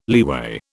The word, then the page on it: leeway: British English